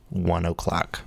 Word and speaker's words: One OK Rock: It's pronounced 1 o'clock.